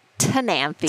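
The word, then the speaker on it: Ctenanthe: Now, this includes Maranta, Calathea, Sananth. Am I saying that right? – I say it Tananthi. – Tananthi. – So the C is silent. C-T.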